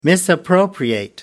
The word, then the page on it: misappropriate: American English